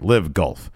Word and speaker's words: LIV Golf: The Saudi Arabia Public Investment Fund bankrolled, is this pronounced Live Golf or LIV Golf? – Do I think it's Live. Live. – All right, Live Golf.